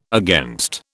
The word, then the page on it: against: British English